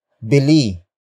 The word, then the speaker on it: bili: Pagbilhan is spelled as P-A-G-B-I-L-H-A-N. It is a command to buy from somewhere. Pagbilhan comes from the root word bili. Bili. Bili, which is spelled as B-I-L-I.